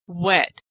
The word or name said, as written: wet